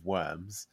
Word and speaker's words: Worms: So in 1521, at a council known as the Diet of Worms, which written out looks like Diet of Worms, but it's pronounced the Diet of Worms.